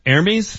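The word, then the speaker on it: Hermès: And this is the Hermes? Is that how you pronounce it? – Yes, $41,000 man purse.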